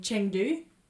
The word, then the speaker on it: Chengdu: And Chengdu.